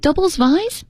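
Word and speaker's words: Doubles vies: So the main contenders this year include Is it Doubles Vise? – Yeah. – Is that how you pronounce that? – Yeah, yeah.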